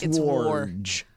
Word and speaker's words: Wojnarowski: It says we call him Woj, but it's War. – It's Worj. – I mean, it's a Polish name, and I guess that's how you pronounce it. – Wojnarowski.